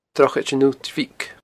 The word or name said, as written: Drochaid an Uillt Bhig